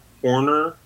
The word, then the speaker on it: Hornor: They can find me on Twitter at John Horner, And my name is spelled H-O-R-N-O-R. It's exactly like horror, actually, except strike the second R and replace with an N. John Horner, all one word, all lowercase, or I guess it doesn't really matter on Twitter.